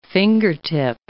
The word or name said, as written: fingertip